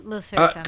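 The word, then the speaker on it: LE SSERAFIM: Even though it's not what we think it is, but it should be pronounced that way, right? – Yes, Le Seraphim. – Correct, yes.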